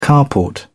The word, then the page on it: carport: British English